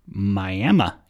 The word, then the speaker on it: Miami: There's a town way up in the panhandle of Texas, a tiny little town, and it is pronounced Miami, even though it's spelled exactly like the city in Florida.